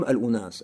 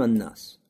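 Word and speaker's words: ан-нас (ٱلنَّاس): Поэтому не говорим «аль-у-нас», а говорим «ан-нас», люди.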